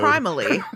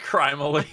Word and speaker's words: Crime Alley: It's Crime-ally. – It's pronounced Crime-ally. Yeah. Yeah. That's what they say, and then we go there, and we're like, wait, this says Crime Alley.